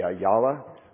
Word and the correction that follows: Aliyah: I think that's how you say it, Aliyah,